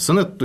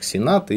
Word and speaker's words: Сенет: Сенет иногда произносится как Сенат или Сенета даже, как женский род. Вот. Но в русском языке устоявшееся название именно Сенет.